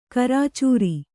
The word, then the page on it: ಕರ್ಚೂರ: kannada